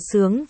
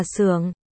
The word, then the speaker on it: Xưởng: Từ này cha Hán Việt thì có hai âm đọc là Sướng và Xưởng. Dùng cho tên hoặc họ thì là Xưởng.